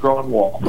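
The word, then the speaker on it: Grönwall: From what I'm told, it's just straight up Grunewald.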